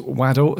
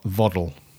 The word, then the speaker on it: Waddle: He said it's uh, it's Waddle, as in the football player, not Voddle.